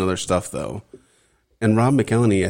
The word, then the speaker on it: McElhenney: So October 1st, 2008, Caitlin Olsen weds Rob McElhenney.